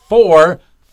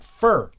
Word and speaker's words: four: He said, my dad, who's in radio, told me to pronounce four, not fur.